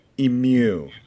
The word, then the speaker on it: emu: Am I pronouncing that wrong? Emu? Is there like a, you got like a little extra note in there? – And Australians tell me they were happy with my pronunciation. – Emu. A little bit of mew in there.